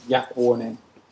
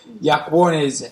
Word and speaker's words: Iacuone: Iacuone is how you would pronounce it, but many Italians might pronounce it Iacuone, and many Australians might pronounce it Iacuone, Iacuone. The lucky one.